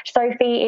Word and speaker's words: Sophie: Sophie is spelled S-O-P-H-I-E.